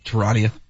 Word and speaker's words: Charania: Charania.